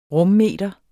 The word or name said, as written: rummeter